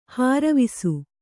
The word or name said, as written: ಹರಿವಾಸ